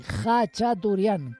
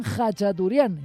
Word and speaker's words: Khachaturian: Por cierto, se dice así, Khachaturian, con una J bien hermosa de las nuestras. Porque claro, pues los anglosajones e incluso los germanos lo escriben con una KH, pero eso es un intento de tratar de escribir la pronunciación. Pero bueno, pues en su idioma es Aran Khachaturian, y además así, en agudo, porque este hombre murió hace 45 años, el 1 de mayo de 1978.